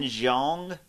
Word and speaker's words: Jeong: Ken Jeong? That's how you say it, right? J-E-O-N-G.